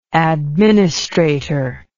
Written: administratorship

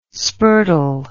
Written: spurtle

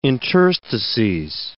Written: interstices

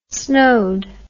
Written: snowed

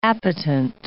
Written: appetent

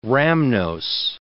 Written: rhamnose

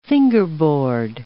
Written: fingerboard

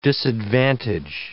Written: disadvantage

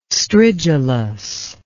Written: stridulous

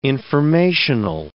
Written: informational

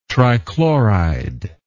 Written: trichloride

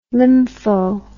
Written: lympho

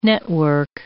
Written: network

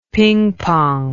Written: pingpong

